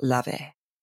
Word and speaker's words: Laufey: Again, you spell it, we'll link to the show notes, but it's spelled L-A-U-F-E-Y. It's pronounced Lave.